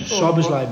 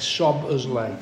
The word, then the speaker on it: Szoboszlai: Szoboszlai, mate. Szoboszlai. – Szoboszlai, I think it is. Is it? Szoboszlai,